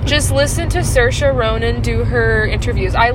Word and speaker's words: Saoirse: You pronounce her name with an Irish accent. Saoirse. Oh. Saoirse Ronan. – Ronan. All right. – And it's pronounced Cerise, and it's funny because she's like, everyone pronounces my name Cerise. It's Saoirse. But yeah.